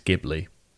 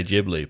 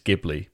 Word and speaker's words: Ghibli: So in Japanese, it's Ghibli. – Great. – A lot of people in English say Ghibli, but it is Ghibli.